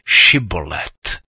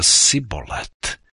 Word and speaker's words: Šibolet: Jestliže odvětil, nejsem, vyzvali ho, tak řekni Šibolet. On však řekl Sibolet a nedokázal to přesně vyslovit. Tu ho popadli a zabili při jordánských brodech. Toho času padlo z Efraima 42 tisíce mužů. Galáckým se podařilo efraimské porazit. Obsadili jordánské brody a efraimští nemohli utéct. Pak našli zvláštní poznávací heslo, které efraimští neuměli vyslovit, protože obsahovalo š.